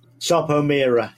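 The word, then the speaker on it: Sarpo Mira: How do you pronounce it? Sarpomira, yeah.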